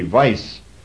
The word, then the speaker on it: wise: And then the other part, W-I-S-E, means way, actually. That was the old word for way. would be pronounced, the W pronounced like a V, vice, Rita vice, and it came over into our language, into modern English, as righteous.